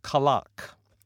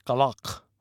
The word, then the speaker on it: Kalak: Okay, music we have from a group called Kalak. That's K-A-L-A-K. If it was Q-A-L-A-K, it would be Kalak, but it's Kalak, spelled with K's.